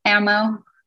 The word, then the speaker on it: Amo: No, it's Amo. – Amo. – It's Amo. – Like ammunition. Yeah.